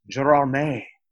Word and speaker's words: Gérardmer: And then even a sort of a bigger festival, it's pronounced Gérardmer.